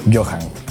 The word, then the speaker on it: Johan: ¿Es John Julio o Johan Julio? – Johan. – Tiene nombre de crack. – Pero se escribe Johan con J. Con doble J. – ¿Cómo? – No con H. – O sea, J. – O sea, se lee Johan. – No es cierto. – Te lo juro. Es J-H-O-J-A-N.